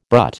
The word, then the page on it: brut: American English